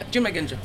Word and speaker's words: Junmai Ginjo: Yeah, Junmai Ginjo.